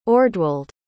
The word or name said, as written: ordwald